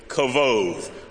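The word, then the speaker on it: kavod (כבוד): The Hebrew word for glory is kavod. Okay, you're going to learn your dose of Hebrew and Greek this morning. Repeat after me, kavod. Kavod. It's transliterated K-A-B-O-D, but pronounced kavod.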